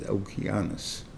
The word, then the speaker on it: Okeanus: It's a Latin word, and the word is Okeanus, or Greek word, maybe, and the thing, Dalton. And therefore, it's a hard C. We just mispronounce it, and therefore, we call it ocean. But the thing is, there's a soft C too, but we mispronounce it, but really Okeanus.